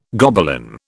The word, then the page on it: Gobelin: British English